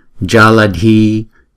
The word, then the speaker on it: Jaladhi: Chữ J này à, đọc là J, J, chứ không phải là J. Không? J là C, chữ C là J, không? Còn là J. Jaladhi, Jaladhi. Chữ D và chữ H thì chúng ta phải đọc là D, D, Đi, hai nhịp. Chữ Jala là một nhịp, phải không? Ja là một, Ja là một nhịp, La là một nhịp, Đi là hai nhịp.